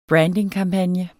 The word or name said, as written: brandingkampagne